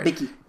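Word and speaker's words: Bikke: Bicky.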